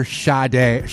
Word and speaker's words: Sade: Just listen to your Sade.